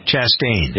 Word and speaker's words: Chastain: Do you pronounce her name Chastain? – Chastain. Yeah.